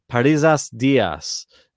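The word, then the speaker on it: Parrizas Díaz: It's Nur- Nuria Paria, uh, I'll say it right. Parrizas Diaz is her name.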